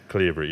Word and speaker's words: Cleobury: Cleobury, yeah, yeah.